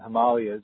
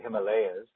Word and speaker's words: Himalayas: So when someone is receiving this energy, Sanjay, then that is being transmitted remotely from the Himalayas or the Himalayas, depending on the pronunciation, then their consciousness is expanding.